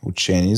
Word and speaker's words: учени: А, има една много палова новина, която казва, че а, някакви учени, учени с ударението на ЕНИ, са създали първата социална мрежа, тук е в кавички и са намигване, но първата социална мрежа от мозъци.